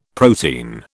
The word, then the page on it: protein: British English